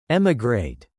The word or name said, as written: emigrate